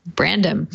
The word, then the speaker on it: Brandom: How do we say his name? Brandom? Brandom.